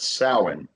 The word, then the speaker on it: Samhain: It's technically pronounced Samhain. – Samhain. – And it's weird because a lot of words of that vocabulary from that time period were spelled completely differently than they were pronounced.